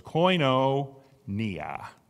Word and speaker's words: koinonia: Koinonia. The W, what looks like a W, is pronounced like an O. So it's koinonia.